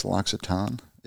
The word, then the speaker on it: L'Occitane: I'm shopping for Mick, and I think it's L'Occitane is how you're supposed to pronounce it.